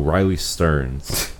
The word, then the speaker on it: Riley Stearns: It's like Riley Streams, I believe. Let me see. Ba-ba-ba. And this is somebody who... Yeah. Oh, Riley Stearns.